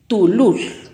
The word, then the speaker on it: tulul: Zapote en pocomam se pronuncia tulul, tulul.